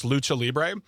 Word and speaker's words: Lucha Libre: Yeah, so it's Lucha Libre. Lucha. It's pronounced Lucha Libre.